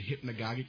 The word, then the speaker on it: hypnagogic: What happened during the hypnagogic state, is that how you say it? – Mm-hmm.